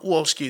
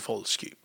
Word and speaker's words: Wolski: I'll never pronounce a Polish name without pronouncing the W properly. So it's not Wolski, it's Wolski.